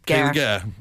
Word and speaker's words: Gare: Kaylee Gare. Rhymes with Cher.